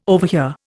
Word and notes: overhear: Indian

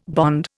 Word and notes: bond: Indian

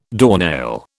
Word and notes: doornail: British English

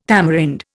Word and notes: tamarind: Indian

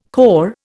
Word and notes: corps: Indian

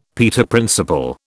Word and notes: Peter Principle: British English

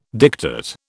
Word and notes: diktat: British English

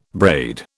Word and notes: braid: British English

